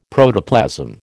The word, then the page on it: Protoplasm: American English